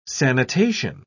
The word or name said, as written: sanitation